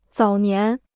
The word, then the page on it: 早年: zǎo nián